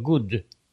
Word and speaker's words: good: Слово good так не произносится. Double O, когда две буквы O, они... Я уже, по-моему, вам это говорил. Ладно, повторение мать учения. Тем более в английском языке. Good. Посмотрите на меня. Я не вытягиваю губы.